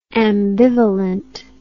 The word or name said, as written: ambivalently